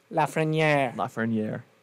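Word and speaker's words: Lafrenière: I mean, it really did look like a confidence that we haven't seen out of Lafreniere. I think that's how you say his name. – Lafreniere.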